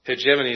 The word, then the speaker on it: hegemony: Hegemony is how that word's pronounced.